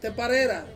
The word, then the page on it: teparera: Ansus